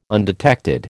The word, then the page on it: undetected: American English